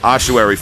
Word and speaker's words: Ossuary: Let's talk about Oshu- uh, Oshuary from they're from Madison, Oshuary, Wisconsin. Yeah. Uh, I, I pronounced it wrong. Long Island, Ossuary from Madison, Wisconsin, right?